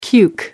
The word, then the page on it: cuke: British English